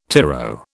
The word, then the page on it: tyro: British English